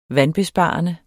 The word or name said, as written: vandbesparende